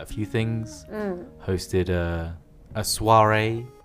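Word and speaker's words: soirée: So soiree is spelled S-O-I-R-E-E with a 第二个 accent. – Soiree. – E-E. And the first E has the upwards. So soiree, it's a French word.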